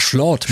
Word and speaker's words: Schlot: Das ist nicht dein Der Erpelschlot, ja. – Ach, Schlot, muss man sagen. – Schlot. – Das waren zwei O, ich habe es einfach so ausgesprochen. Ja.